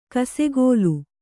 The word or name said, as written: ಕಿಸುಗಲ್ಲು